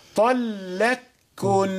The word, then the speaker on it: طلقكن: أدغمت في يعني كما أنطقها هكذا، طلقكن. لا نحاول أن نأتي بصفة الإدغام في القاف.